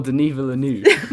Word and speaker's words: Denis Villeneuve: Denis Villeneuve? – Oh, Denis Villeneuve.